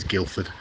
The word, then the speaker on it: Guildford: By the way, it's Guildford is how it's spelt, if you want to look it up on a map. But everyone drops the D and just says Guildford.